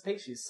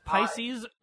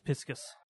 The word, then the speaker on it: Pisces: Feffery and Mina as Pacey's sus. – Pacey's? – Yeah. – Isn't it Pisces? – It's Pisces, unless you are Japanese, in which case it's Piscus.